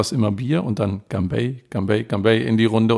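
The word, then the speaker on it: Ganbei (干杯): Ja, Ganbei sagt man immer, wenn man anstößt. Das hast du richtig ausgesprochen. Aber als ein richtiger Nordchinese sagt man Ganbei, Gan, nicht Ganbei, okay, sondern ja, sehr männlich.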